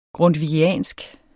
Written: grundtvigiansk

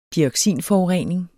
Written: dioxinforurening

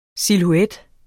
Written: SILHUETTERNES

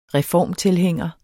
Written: reformtilhænger

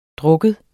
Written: drik

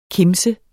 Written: kimse

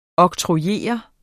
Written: oktrojere

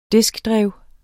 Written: diskdrev

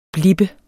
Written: blippe

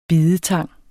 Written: bidetang

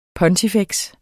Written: pontifex

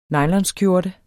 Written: nylonskjorte

